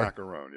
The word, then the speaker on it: Macaroon: Is it Macaroon, like the cookie? – It's or? – Macaroon, yeah.